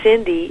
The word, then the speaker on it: Cindye: And Cindy is spelled C-I-N-D-Y-E.